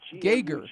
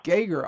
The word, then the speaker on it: Gager: Well, Beatrice, it's Gager. – Gager. – G-E-R is how it's spelled, but boy, that sure doesn't help you. So Gager, yeah. – Gager, all right.